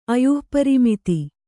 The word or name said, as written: ಅಯುಃಪರಿಮಿತಿ